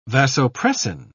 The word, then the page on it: vasopressin: British English